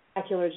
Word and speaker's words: macular: She had immaculate degeneration. – Macular degeneration.